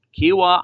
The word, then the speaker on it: Kiawah: This is your chance to win a VIP experience at the 2021 PGA Championship at the world-class Kiawah Island Golf Resort. Kiawah Island Golf Resort.